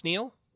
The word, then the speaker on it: Niall: Is that how you say it? Neil? – I believe it's Neil. – Okay. It's spelled N-I-A-L-L. So yeah. – I wasn't sure. – Could be Niall. I'm under the impression that that's just an alternate way to spell Neil.